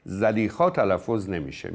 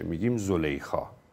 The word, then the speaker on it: زلیخا: البته در تلفظ عمومی زلیخا تلفظ نمیشه، میگیم زلیخا، اما خب اصل کلمه زلیخاست